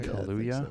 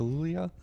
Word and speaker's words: Kaluuya: Ansel Elgort, Baby Driver, James Franco, The Disaster Artist, Hugh Jackman, The Greatest Showman, and Daniel Kulia. Am I pronouncing that right? Kaluuya. So. Kaluuya. Kaluuya.